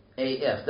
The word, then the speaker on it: est: Sat is literally the neuter singular present participle of the verb us, to be, AF. That's where we get the English word is, asti. We get the English word is, German ist, French est, which they pronounce a, but it's written est.